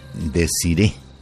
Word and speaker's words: Desiré: Pues es un nombre, Desiré, con acento en la E.